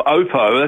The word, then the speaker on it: Oppo: The Watch X3 from Oppo, that's how you pronounce it, by the way, yeah. Oppo, I was told by Oppo themselves,